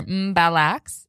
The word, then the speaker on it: Mbalax: I'm grateful Matt put a pronunciation guide there. Mbalax.